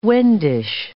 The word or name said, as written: Wendish